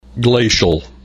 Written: glacial

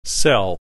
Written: cell

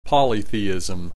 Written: polytheism

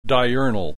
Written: diurnal

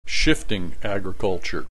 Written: shifting agriculture

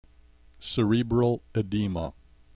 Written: cerebral edema

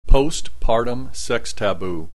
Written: post partum sex taboo

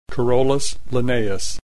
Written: Carolus Linnaeus

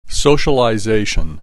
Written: socialization

